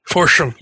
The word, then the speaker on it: Forstchen: But if people are interested in emergency management, a related book, one book that I really like is called 1 Second After by William R. Forstchen, I think is how you say his name. – Forstchen, yep.